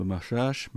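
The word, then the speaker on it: Macháč: Sinner s'est totalement rassuré, après un match un peu hésitant contre Mahach, faut le prononcer à la tchèque.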